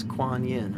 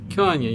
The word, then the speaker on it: Kuan Yin: And recently, when I was on vacation, I chanted Kun Yunj again, and then I saw dolphins. – And just to step in, that's Kuan Yin, right? – Kuan Yin, yes. I couldn't pronounce it.